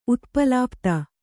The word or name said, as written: ಉತ್ಪಲಾಪ್ತ